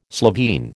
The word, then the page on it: Slovene: American English